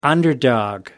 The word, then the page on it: underdog: American English